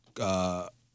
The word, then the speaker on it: clonazepam: It sounds like the drug was clonazepam. I don't know if I'm saying that right. – Yeah, sounds right.